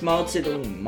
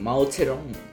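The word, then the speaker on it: Mao Zedong (毛泽东): Und um 1960 machte sich, okay, jetzt muss ich das aber richtig aussprechen, Mao Zedong. – Nicht Mao Zedong, Mao Zedong.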